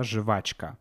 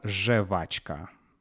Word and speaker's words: жвачка: Да, жвачка. Ну, пишется жвачка, но мы говорим обычно жевачка да, жвачка.